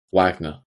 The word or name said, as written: Wagner